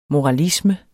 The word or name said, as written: MORALISMEN